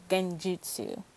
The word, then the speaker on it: genjutsu: genjutsu.